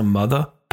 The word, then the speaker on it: Mutha: I met a woman named Sophia, who is a founder and host of the As a Mother podcast. I pronounce it like that because it's spelled M-U-T-H-A.